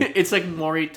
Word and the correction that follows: Maori: it's like, what's it, the Maury, like, tattoos? Is that, am I saying that right? – Oh, like. – Yeah, in, like, the Polynesia, or, like, the. – Yeah, Maori. – Is it Maori, thank you.